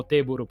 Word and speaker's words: Teburu: ufficializziamo Teburu perché appunto come ci diceva Alessandro prima eh, deriva dalla eh, diciamo dalla pronuncia alla giapponese.